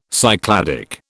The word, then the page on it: Cycladic: British English